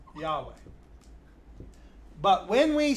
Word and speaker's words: Yahweh: which we pronounce Yahweh.